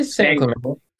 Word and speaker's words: San Clemente: What is it, Clemente or Clemente? – I say San Clemente.